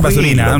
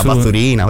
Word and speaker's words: Baturina: Ma la pronuncia è Baturina, non mi fido. – Baturina, perché no, sui giocatori dei balcanici.